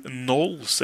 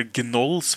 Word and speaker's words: Gnolls: Det da Frostgrave Knolls, eller Gnolls, hvis du vil uttale G-en.